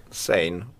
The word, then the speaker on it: Zayn: Att jag gav eh, Zayn, nu uttalar jag säkert namn fel. – Zayn.